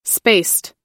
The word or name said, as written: spaced